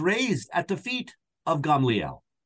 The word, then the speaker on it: Gamaliel: Gamaliel, mm-hmm. whom you see not only in Acts chapter 5, we usually say Gamaliel, right? But Gamaliel is how we pronounce it.